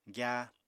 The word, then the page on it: gyáa: apatani